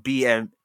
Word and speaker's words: BAME: Don't BAME shit. Like, don't like none of that. I'm black. – How do you say it? BAME? – Bam.